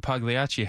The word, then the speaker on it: Pagliacci: I thought you said Pagliacci had a deal. – Oh, there it is, Pagliacci. Soft G, bro.